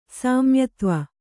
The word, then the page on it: ಸಾಮ್ಯತ್ವ: kannada